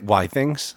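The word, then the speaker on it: Withings: Jeremy has a thing called the Whittings Smart Body Analyzer, which is basically incredibly expensive bathroom scales, and he's going to give us a review of them. – Isn't it Whittings? – Indeed it is.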